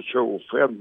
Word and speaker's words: чоуфан: Дело в том, что блюдо чоуфан, действительно это так произносится, "чоуфан", если по-русски, по словарю э, с, э, с кириллической Чай.